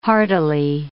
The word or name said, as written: hardily